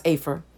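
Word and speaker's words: Afer (אֵפֶר): is the hebrew word ephor, okay and or Afer, it's pronounced Afer,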